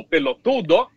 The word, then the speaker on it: pelotudo: O cara diz boludo com mais ênfase na segunda sílaba ou pelotudo na terceira sílaba, não é?